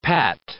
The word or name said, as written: pat